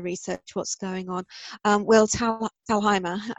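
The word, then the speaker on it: Thalheimer: I spent years pronouncing Will's name wrong before he told me, no, Donald, it's actually pronounced, it's the German way, Talheimer. So yes, you pronounce it right.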